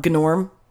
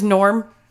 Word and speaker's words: Gnorm: Can you, uh, did you ever watch the fucking it's an old like 80s and 90s movie called um Gnorm. No. The Gnome. No. So his name is Norm, but it's it's spelled G N O R M.